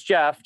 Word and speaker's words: Jeff: So my name is spelled, so it's Jeff, J-E-F-F, but last name is P as in Paul, F as in Frank, I-T-Z-E-R.